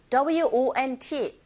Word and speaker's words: won't: 특히 WONT, 요게 이제 원하다라는 동사와 비슷하게 들린다고요? 아니요, 다릅니다. 입을 동그랗게 한 상태에서 won't, won't 그러면서 은 사운드 내주셔야 되는데 강한 것이 아니라 약하게.